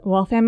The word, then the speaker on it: Waltham: Waltham, Massachusetts.